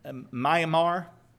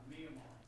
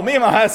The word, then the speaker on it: Myanmar: Jeremy just got back from a missions trip, and I know I'm going to mispronounce this, so y'all please correct me. Myanmar? Okay, nobody knows, because I butchered it so bad, it's not even a place. Anyway, somebody can tell me later, because I know I said it wrong. But what? – Myanmar. – You can tell me tomorrow? Oh, Myanmar. I got you.